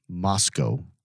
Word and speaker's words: Moscow: Mos-cow. – Mos-cow. We're not saying Mos-cow. – Nope, because we are in America. – It is spelled the same, but we're pronouncing it completely different.